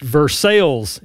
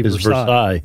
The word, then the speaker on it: Versailles: But I will say it's pronounced Versailles in Kentucky, even though the place in France might be Versailles.